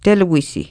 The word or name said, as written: teluisi